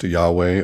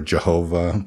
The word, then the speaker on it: Yahweh: the J source, which comes from the Hebrew tetragrammaton YHWH, pronounced Yahweh or Yehovah or Jehovah by Germans.